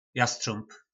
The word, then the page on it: Jastřómb: Po našimu